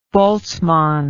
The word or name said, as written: Boltzmann